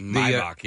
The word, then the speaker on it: Maybach: Maybach? How's it pronounced? – Maybach, yeah.